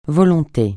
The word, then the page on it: volonté: francais